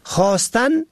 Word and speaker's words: خواستن: این دو کلمه خواستن و برخواستن یک گونه تلفظ می شود، اما در نوشتن و معنا با هم متفاوتند. خواستن یعنی تقاضا، خواستن با واو یعنی تقاضا کردن، خواهش و درخواست، مانند وی از من خواست تا برایش کمک کنم.